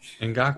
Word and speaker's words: Ngakoue: I don't even know how to pronounce his name, Yannick Ngakoue. – What have you. There you go.